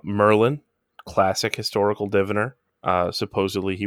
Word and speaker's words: Merlin: I think it's pronounced Merlin though. Because the DD in Welsh is an L.